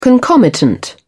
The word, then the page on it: concomitant: British English